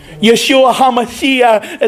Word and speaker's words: Yeshua HaMashiach: Yeshua HaMashiach is how you say it in Hebrew.